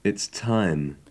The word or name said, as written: its time